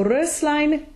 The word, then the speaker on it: Röslein: But when we add the ending of Röslein, the S unvoices, right? – Ja, auf jeden Fall. Röslein. Und Rose wäre halt, wie du schon richtig gesagt hast, stimmhaft. – Yeah. So Röslein, definitely a closed O umlaut sound and then an unvoiced S.